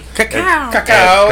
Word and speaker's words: cacao: Cacao is the proper pronunciation.